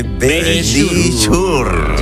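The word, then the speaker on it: Verisure: Dilo tú, que pronuncia mejor. – Sí, venga, dilo. – Nuestros amigos de Belisur. – Ahí lo habéis dicho divinamente.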